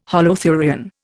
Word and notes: holothurian: Indian

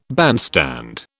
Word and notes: bandstand: British English

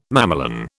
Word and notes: mamelon: British English

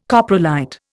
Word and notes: coprolite: Indian

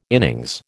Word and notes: innings: American English

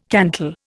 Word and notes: cantal: Indian

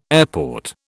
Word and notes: airport: British English

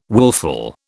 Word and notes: wilful: British English

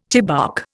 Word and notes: chibouk: Indian

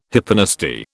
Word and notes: hyponasty: British English

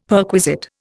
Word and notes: perquisite: Indian

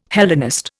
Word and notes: Hellenist: Indian

